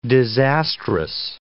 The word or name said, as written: disastrous